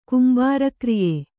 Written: ಕುಂಬಾರಕ್ರಿಯೆ

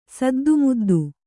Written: ಸದ್ದುಮುದ್ದು

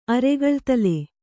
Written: ಅರೆಗಳ್ತಲೆ

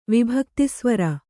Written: ವಿಭಕ್ತಿಸ್ವರ

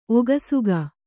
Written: ಒಗಸುಗ

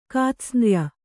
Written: ಕಾತ್ಸ್ನ್ರ್ಯ

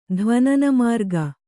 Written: ಧ್ವನನಮಾರ್ಗ

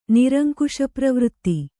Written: ನಿರಂಕುಶಪ್ರವೃತ್ತಿ

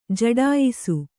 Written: ಜಡಾಯಿಸು

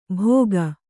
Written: ಭೋಗ

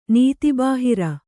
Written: ನೀತಿಬಾಹಿರ

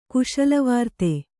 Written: ಕುಶಲವಾರ್ತೆ

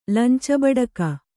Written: ಲಂಚಬಡಕ

